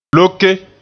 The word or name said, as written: loke